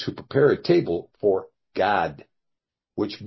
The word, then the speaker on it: Gad: But, of course, we're going to find out that Gad is not pronounced Gad, but rather it's pronounced something else. Gad is not pronounced Gad like in Ad, but God like in God.